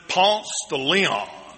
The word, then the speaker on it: Ponce de Leon: Well, if you go there and visit, don't give the Spanish pronunciation of it. We pronounce it Ponce de Leon. That's the way you pronounce it.